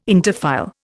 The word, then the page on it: interfile: Indian